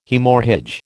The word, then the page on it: Haemorrhage: American English